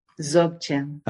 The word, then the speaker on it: Dzogchen: Dzogchen.